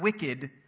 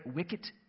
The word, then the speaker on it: wicket: And then there is the, I have to stress the T in this, the wicket gate, not wicked, but wicket gate, that represents the way of salvation.